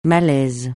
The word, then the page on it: malaise: francais